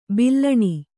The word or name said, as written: ಬೆಲೂನು